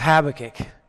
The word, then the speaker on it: Habakkuk: How do we say it in Australia? Am I saying it right? How do you say it? Habakkuk. Anybody here say Habakkuk? So maybe I'm saying it the wrong way, but it's either Habakkuk. It's Habakkuk. Okay, it's Habakkuk.